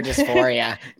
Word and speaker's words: dysphoria: Gender dysphoria.